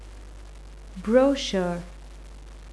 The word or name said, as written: brochure